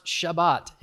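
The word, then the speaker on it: Shabbat (שבת): It's a Hebrew word. It's pronounced Shabbat in Hebrew. We just transliterated it into English and we say Sabbath.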